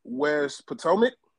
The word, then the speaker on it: West Potomac: Coach, last week you guys played West Potomac, saying that correctly? – Yep. – West Potomac, okay, and you guys crushed them 42-19.